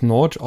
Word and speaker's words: Nagy: Ich glaube, man muss das Nagy aussprechen.